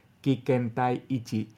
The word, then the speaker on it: Kikentai Ichi: Así se pronuncia, Kikentai Ichi.